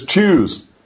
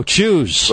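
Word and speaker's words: Tschüss: It's choose. – Oh, choose.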